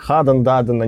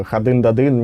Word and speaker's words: Хадн Дадн: Ну, короче, мы уже вот сейчас до интервью выяснили и разобрались с названием группы, и что оно произносится как Хаден Даден, а не как э, Хадын Дадын, не надо ставить ударение на Д.